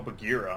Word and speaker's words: Bagheera: Mobily with Bashira. – They're remaking Jungle Book there. – They are, yeah. And that looked really neat. Yeah. Okay. – Anyway. – Oh, Bagheera, I'm sorry, Bashira.